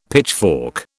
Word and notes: pitchfork: British English

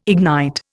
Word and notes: ignite: Indian

